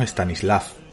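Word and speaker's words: Stanisław: Śpiewał, że czas by Se pronuncia Stanislav, pero todos por aquí decimos Stanislav.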